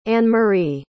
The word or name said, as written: annemarie